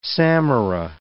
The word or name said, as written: samara